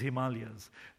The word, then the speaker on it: Himalayas: I was visiting some friends in Nepal, and they took me to the correct pronunciation is Himalayas.